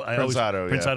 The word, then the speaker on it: Prensado: The Fresnado or Prensado. – Prensado. – I always mispronounce that, but that comes to mind.